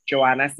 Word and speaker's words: Joanna: But I'm on all the social medias as Joanna Cifredo, and that's spelled J-O-A-N-N-A, last name Cifredo, C-I-F-R-E-D-O.